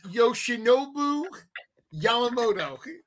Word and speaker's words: Yoshinobu Yamamoto: It is Yoshinobu Yamamoto.